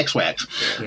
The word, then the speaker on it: XYX: It's Neo XYX? Zeex? – Yeah, XYX. Yeah, XYX.